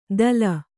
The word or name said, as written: ದಲ